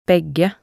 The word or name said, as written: begge